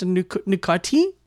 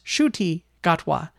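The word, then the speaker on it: Ncuti Gatwa: And I always thought it was pronounced Nukuti. N-C-U-T-I is how you spell it. But I have learned that his name is pronounced Shuti Gatwa.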